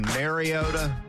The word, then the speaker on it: Mariota: Well, look, let me first get the pronunciation correct. I hear a lot of people going Mariota. It's not Mary. It's Mariota. Get it right, please.